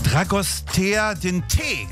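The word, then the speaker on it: Dragostea din tei: Wie spricht man das eigentlich? Dragostea den Tee, glaube ich. – Ja, Tee.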